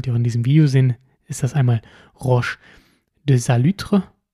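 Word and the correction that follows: Roche de Solutré: Entschuldigung, Roche de Salutré, so wird es ausgesprochen, und Roche de Vergisson.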